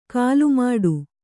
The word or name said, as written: ಕಾಲುಮೆಟ್ಟು